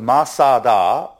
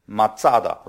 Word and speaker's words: Masada: I remember when we went to Israel, we thought it was Masada, but when you got there, you know there was a Tzade there. They pronounce it Matsada, right? Right, it almost sounds like matzah bread.